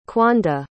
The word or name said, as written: quanda